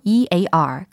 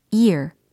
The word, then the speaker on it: ear: This year, 올해에 해당하는 year의 발음은 year, year, 요렇게 됩니다. year. 근데 귀에 해당하는 단어 있잖아요. ear. 그게 바로 ear, ear. 요렇게 발음이 되는 거예요.